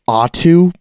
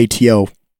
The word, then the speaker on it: ATO: He technically pronounces it Atu, but I just don't like that pronunciation. So I'm just going to say ATO, probably.